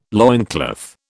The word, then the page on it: loincloth: British English